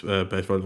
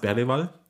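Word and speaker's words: Bergvall: Und ja, also halt mit Gray und Bergwall und jetzt habe ich Bergwall gesagt. Das ist völlig falsch. Es ist ein Video kursiert, wo er seinen Namen ausspricht. Die richtige Pronunciation ist tatsächlich Lukas Bergwall.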